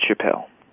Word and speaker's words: Chappell: Yeah, it's Chappell.